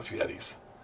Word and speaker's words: Fieri: I'm here with the Fieris.